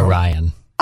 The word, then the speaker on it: Orion: The First Orion. So did I say it right? – No. Orion. – Oh, I said it wrong again. Sorry. Well, it's like Orion's belt.